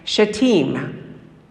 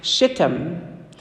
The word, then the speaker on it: Shittim: The men come from a city whose name is pronounced, if you're British, the name of the city is pronounced Shittim. Okay, if you're American, the name of the city is pronounced Shittim.